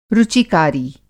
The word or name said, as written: रुचिकारी